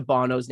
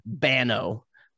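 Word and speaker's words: Banno: It's like, if you were to say it with an American accent, it'd be Bano, but it's B-A-N-N-O. So it would be pronounced Bono.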